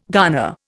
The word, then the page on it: garner: Indian